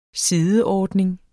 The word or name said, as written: sideordning